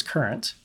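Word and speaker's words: Kurent: Now, number three has a funny spelling, but it's probably going to be pronounced current. – Current. That's right. It's K-U-R-E-N-T.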